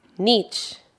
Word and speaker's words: niche: Niche, niche. – 틈새. 이거는 niche라고 읽어도 되고요. niche라고 읽어도 돼요. 거기다 써보세요. niche, niche 다 됩니다.